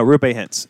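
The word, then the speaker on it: Roope Hintz: No, Rupe Hintz.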